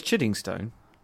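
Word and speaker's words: Chiddingstone: Now, I've been pronouncing the place you're from incorrectly. Right. So how do I pronounce it? – Oh, it's Chiding Stone.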